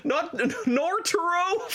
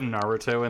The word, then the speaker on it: Naruto: Norturo from Norturo. – I want to punch you for that one because you know how to say it now and you just said it wrong on purpose.